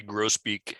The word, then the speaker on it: grosbeak: Well, I this really isn't a question, but grosbeak, you pronounce the S in grosbeak or not? Is it grosbeak or grosbeak for a bird? – We say grosbeak. – Grosbeak, okay. – Yeah. We've anglicized it.